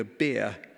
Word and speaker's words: bier: And they would be carried on this type of coffin called a bier, pronounced like the thing that some of us like to drink, but spelt B-I-E-R.